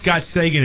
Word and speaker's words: Gottsagen: You pronounce it Gottsagen? – I think it's Gottsagen is what the directors pronounced it.